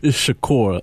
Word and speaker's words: Shakur: It's Shakur. – Shakur. – Like Tupac. – Like Tupac Shakur.